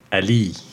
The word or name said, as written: Ali